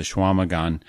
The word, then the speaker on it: Chequamegon: And folks, if you want to look that up on a map in northern Wisconsin, the Chequamegon, that's how it's pronounced but I have no idea how it's spelled because eh, it's got a lot of letters that don't make sense.